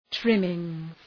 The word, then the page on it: trimmings: ‘trımıŋz